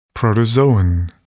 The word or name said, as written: protozoan